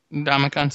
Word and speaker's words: Ndamukong: Ndamukong Suh? – Yes.